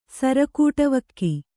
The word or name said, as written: ಸರಕೂಟವಕ್ಕಿ